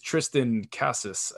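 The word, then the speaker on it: Triston Casas: Well, the Red Sox have a their number one prospect is number 19 in all of baseball is Tristan Casas, I believe is how you say his name.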